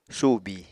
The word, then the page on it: subi: Apatani